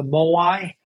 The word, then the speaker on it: Moai: Well, riffing off of that, in Okinawa, they have this beautiful practice of the Moai. It's spelled M-O-A-I.